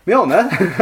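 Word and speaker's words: Mjölnir: Anyway, Mjolnir, pronounced... Mjolnir. – Mjolnir, pronounced Mjolnir. – Because that was in the text, listener, and I read it out loud. It's like an alter key moment. Anyway, Mjolnir, which is pronounced as Mjolnir.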